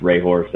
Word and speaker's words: Hrehor: Uh, so that's my last name pronounced Rayhor, so N Rayhor Photo or N H R E H O R dot F O T O.